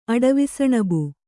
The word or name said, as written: ಅಡವಿಸಣಬು